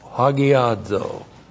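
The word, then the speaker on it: hagiazō: Looks like that in the Greek, H-A-G-I-A-Z, long O, hagiadzo.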